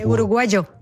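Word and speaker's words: uruguayo: De Mauro, non il signore ma il dizionario, registra un uruguagio che viene dallo spagnolo americano, si scrive uruguaio con la Y, ma in Uruguay si legge uruguagio.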